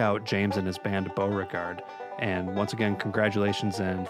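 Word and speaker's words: Bowregard: The band is called Beauregard, and that's spelled B-O-W, like a violin bow, and then regard, all as one word.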